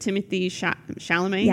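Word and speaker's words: Timothée Chalamet: We talked about this earlier, but Timothy Chalamet. Yes. Am I saying it right? – Yes.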